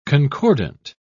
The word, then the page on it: concordant: British English